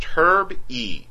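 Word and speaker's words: H.E.R.B.I.E.: Now, rather than using another Marvel character, they came up with the idea of Herb E. That's how it's supposed to be pronounced. Herb E.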